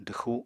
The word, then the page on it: dwxu: apatani